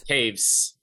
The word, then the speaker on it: Toews: Is that how you say his name? Toews? – Taves. – Taves, yep, that guy.